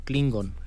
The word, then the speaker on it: Klingon: Otro, otro Miguel nos dice, bueno, Mora Azuk dice, Nel, todos aprendamos Kling, Klingon. ¿Lo dije bien? Sí.